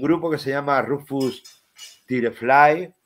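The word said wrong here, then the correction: Rufus T. Firefly: Espera, espera que me la, me Sí, eh, será Rufus, Rufus Firefly.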